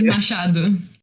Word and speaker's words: Machado: It's Machado.